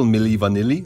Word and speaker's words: Milli Vanilli: Milli Vanilli, so spricht man es aus, auf.